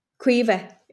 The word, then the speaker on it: Caoimhe: Quiver is her name. – Quiver. Is that an Irish name? – It's Irish, yeah. It's spelled like C-A-O-I-M-H-E, so it's a mad spelling.